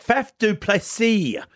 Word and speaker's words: Faf du Plessis: And I tell you, I like Faf du Plessis.